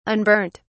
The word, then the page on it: unburnt: British English